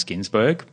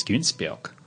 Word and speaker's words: Ginsburg: My name's Ginsburg, but my German cousin's like, no, why do you say your name wrong? It's Günsberg.